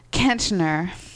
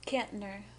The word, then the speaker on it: Kantner: Kantner. That's how you pronounce that name, right? – Kantner.